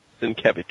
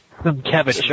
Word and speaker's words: Sienkiewicz: How do you say his name? – Sienkiewicz. I think we should just call him Bill son of a bitch. – Sienkiewicz. All right, Bill Sienkiewicz.